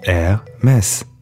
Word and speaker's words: Hermès: To clear up the debate on how to pronounce the name of the fashion house correctly in English, he apparently settled it quite simply by saying, "When airport traffic goes wrong, you say it's an Hermès."